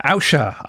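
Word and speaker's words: Ausha: Podcast host Usha, is that how you say it? – Yeah, Usha. I think they're French.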